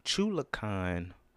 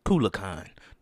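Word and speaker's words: Culiacán: They went to the home of the Sinaloa cartel, which is in Chulacan, Mexico. New Mexico. Mexico, goddammit. Culiacan.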